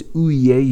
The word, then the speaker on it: Houllier: The pronunciation, as I said before, is Ouye. You don't actually pronounce the double L.